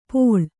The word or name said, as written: ಪೋಳ್